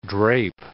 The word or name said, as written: drape